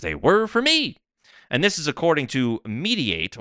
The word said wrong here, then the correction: Mediaite: or how do we say this? Mediaite, excuse me.